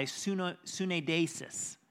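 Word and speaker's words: syneidesis: This word conscience that Paul uses both in chapter 23 and 24, it's the same Greek word that can be pronounced by sunedesis.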